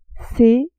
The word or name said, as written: c